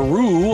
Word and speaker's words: Roo: Dr. Wellner, who is the human, and Dr. Rue, or if I pronounce that correctly, who is in the picture behind you, correct? – Yes.